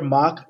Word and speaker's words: Mach: As a side note that doesn't matter, I pronounce my own name either Mach or Mac. My family pronounces it Mac.